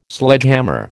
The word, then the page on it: Sledgehammer: American English